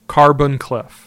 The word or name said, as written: Carbon Cliff